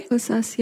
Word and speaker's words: Kosas: Kosas, yeah.